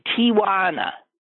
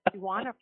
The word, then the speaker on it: Tijuana: Here's Doug is saying, mispronounced words, his is Tijuana. I just mispronounced it according to him. It's Tijuana, not Tijuana. – No A. It's T-I-J-U-A-N-A. – J. T-I-J-U-A-N-A.